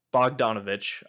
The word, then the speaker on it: Bogdanovich: So essentially, Peter Bogdanovich, I hope I pronounced that right. – You got the name right. Yeah, you did.